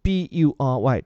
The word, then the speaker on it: bury: 后面这个 burying 这个词 B U R Y，注意它的发音叫做 bury，这个 U 发的是 a 这样的一个音，相对比较特殊，埋葬的意思。